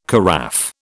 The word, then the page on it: carafe: British English